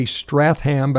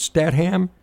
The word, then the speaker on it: Statham: I would try to say Stratham, but I think it's Statham.